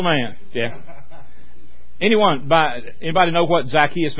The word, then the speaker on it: Zacchaeus: I ain't heard it pronounced like that. I've always called it Zacchaeus. Is it Zacchaeus. – Zacchaeus. Yeah, in the Greek text, the accent is on the Epsilon.